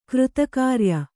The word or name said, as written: ಕೃತಕಾರ್ಯ